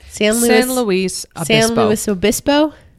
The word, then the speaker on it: San Luis Obispo: San Luis Obispo.